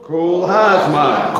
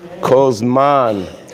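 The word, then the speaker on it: Kol Zman (כל הזמן): Kol Zman. Friends from Israel say, now you know y'all mispronouncing that. It's beginner Hebrew, okay?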